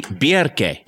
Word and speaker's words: Bjarki: Bjerke. – Yes. Well, Icelanders do love a rolled R, so it's Bjerke. – How about this? – What? – Bjerke.